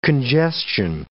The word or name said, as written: congestion